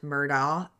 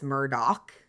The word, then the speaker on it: Murdaugh: And it's not Murdaugh, it's Murdaugh.